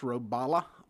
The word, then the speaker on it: Robalo: I, be, I believe it's pronounced Robala, R O B A L O.